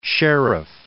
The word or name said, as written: Sherriff